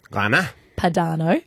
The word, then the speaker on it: Grana Padano: How do you say that thing? – Grana. – Grana. – Padano.